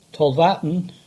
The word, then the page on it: Tolvaddon: English